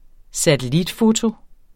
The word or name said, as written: satellitfoto